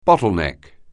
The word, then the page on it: bottleneck: anglais